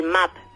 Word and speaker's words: MAB: Ay, pues hoy vamos a hablar del MAP. Se escribe M-A-B.